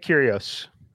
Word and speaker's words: Kyrgios: M squared is on Kyrgios. Is that how you pronounce it? – Did I have it correct? Yeah, I'm on mute over here, but yeah, Nick Kyrgios.